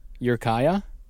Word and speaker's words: Ukiah: And Ukiah, California. – Where is it? – Ukiah. – Oh, okay. – It's Eureka, but with less syllables.